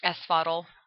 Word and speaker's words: Asphodel: And then the soul would be judged and sent to either Elysium, Tartarus, or, and I'm probably mispronouncing this, the Asphodel Meadows? – Asphodel. – Asphodel Meadows, yes.